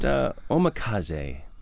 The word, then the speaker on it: omakase: We're talking about nigiri and vinegar, rice and vinegar, and I want to talk about omakase.